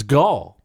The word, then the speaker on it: Gal: And I've been watching, what that led to was me watching a lot of who I thought you pronounce her name, Gal Gadot, but she says it's Gal.